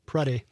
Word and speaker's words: Preddy: Is that Finn? – Preddy. No, Italian. – It is Italian.